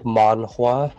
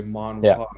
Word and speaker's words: manhwa: It's like manhwa. – I would say manhwa, yeah.